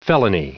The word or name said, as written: felony